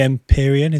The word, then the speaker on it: Empyrean: The Empyrean, is that how you say it? – Yeah.